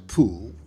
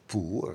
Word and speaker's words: Pool: Ja, Dr. Poel. dat de twee dingen zijn interessant natuurlijk. Eh, Pool. Eh, ja goed, het is logisch dat eh, Engelsen of Amerikanen haar naam zo uitspreken.